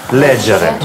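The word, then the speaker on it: leggere: Здесь особенность в том, что в некоторых лицах произносится «г», а в некоторых «дже». – «Легу», «ледже», «ледже». – Да, еще раз. «Читать», «леджере».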